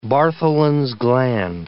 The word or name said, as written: Bartholin's gland